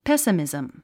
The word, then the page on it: pessimism: pésəmìzm; ペッサミズム